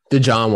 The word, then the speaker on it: DeJohn: Is it DeJohn Watson, or is it just John? Like, what is his name? – DeJohn? How do you say it? – DeJohn Watson.